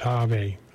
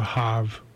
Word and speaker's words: Jave: Spelled with an X, but it says pronounced Jave or Hav.